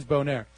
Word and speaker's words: Boehner: I did notice Nancy Pelosi still does not know how to pronounce his name. It's difficult because it's spelled differently, but he is, I think, French-Canadian descent. It is Boehner.